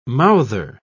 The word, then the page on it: mouther: British English